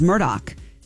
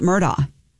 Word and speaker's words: Murdaugh: No, that is how you say his name. – Yeah. – Is Murdoch. It's not Murdaugh.